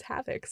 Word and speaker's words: HAVC: Which was shortened to HAVC. – Okay. – Which was then pronounced as Havoc, so I got a degree in Havoc.